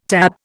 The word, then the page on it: TAB: Indian